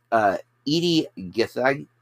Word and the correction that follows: Gathegi: Oh, I'm saying that name wrong. Gathegi.